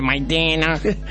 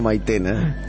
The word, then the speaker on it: maicena: Albacores de maidena. – A ver cómo pronuncia eso. – Alfajores de maicena. – Maicena, no maitena.